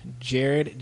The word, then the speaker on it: Jarred: You can sign me at I am Jared, J-A-R-R-E-D, because no one spells my name right.